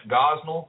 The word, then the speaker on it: Gosnell: And just in recent days, we've in the news, there's been the story of Kermit Gosnell. Is that how you say his name? – Yes.